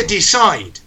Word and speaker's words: decide: Decidir se dice decide, pero se escribe decide. O sea, terminamos en E, decide. Pero a efectos prácticos, fonéticamente, termina en D.